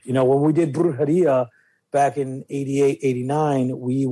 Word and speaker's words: Brujeria: Brujeria.